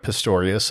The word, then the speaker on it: Pistorius: So as I stated before, my name is Doug Pistorius. That's P-I-S-T-O-R-I-U-S.